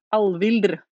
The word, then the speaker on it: Alfhildr: Yeah, I think because we have done very extensive language research in this show, and the right pronunciation, according to my Norse expert, is Alvhildr.